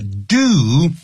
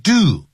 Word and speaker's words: due: And I'm sure they will give them all due consideration，那我相信呢他们会给予他们所有的 due，这个呢千万不要念 due 啊，这个念成 do 啊，也就是呢就是他们会呢做一个适切的 do，代表适切的这些考量啊，就是 proper P-R-O-P-E-R proper consideration。